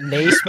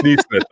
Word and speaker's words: Nesmith: It's not Naismith. – Is he related to? – It's Nesmith. I'm sorry. Yeah. Because I said Nesmith before, and then I wanted to say Nesmith.